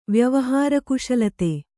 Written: ವ್ಯವಹಾರಕುಶಲತೆ

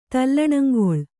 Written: ತಲ್ಲಣಂಗೊಳ್

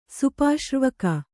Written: ಸುಪಾಶ್ರ್ವಕ

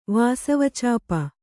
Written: ವಾಸವಚಾಪ

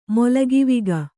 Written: ಮೊಲಗಿವಿಗ

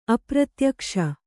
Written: ಅಪ್ರತ್ಯಕ್ಷ

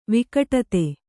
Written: ವಿಘಟಿತ

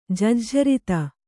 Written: ಜಜ್ಝರಿತ

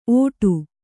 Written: ಔಡು

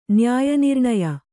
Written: ನ್ಯಾಯನಿರ್ಣಯ

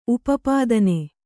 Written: ಉಪಪತ್ನಿ

